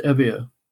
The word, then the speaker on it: Euboea: And he mentions Evia. It's pronounced E-U-B-O-E-A, but it's pronounced Evia by the Greeks.